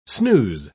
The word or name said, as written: snooze